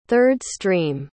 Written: third-stream

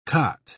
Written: khat

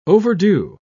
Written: overdue

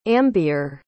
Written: ambeer

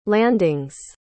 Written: landings

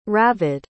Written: ravid

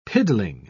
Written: piddling

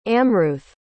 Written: amruth

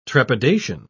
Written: trepidation

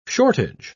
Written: shortage

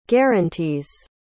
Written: guaranties

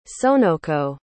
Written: sonoko

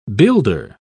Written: builder

